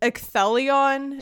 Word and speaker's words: Ecthelion: Ecthelion is another one, but that's a longer word, and the emphasis is on, again, the second one.